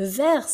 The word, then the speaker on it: vert: Attention de bien prononcer le R dans la gorge. Vert. Puis ensuite, nous avons le bleu.